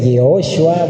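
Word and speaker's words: Yehoshua (יהושע): En hebreo se dice Yehoshua, bueno, ya eso es pronunciation.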